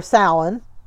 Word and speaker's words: Samhain: The words he did pronounce correctly that we were surprised that he did, because a lot of people don't, in all fairness, were Samhain, which is not pronounced the way you think it is, and Lep.